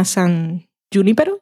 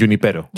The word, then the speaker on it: San Junipero: El cuarto de esta tercera se llama San Junipero, se pronuncia. – San mm, Junipero. – Junipero.